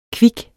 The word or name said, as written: kvik